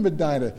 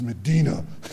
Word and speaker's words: Medina: His name was Medina. I said, "Hey, man, Medina. I was born in Medina." He goes, "No, it's Medina."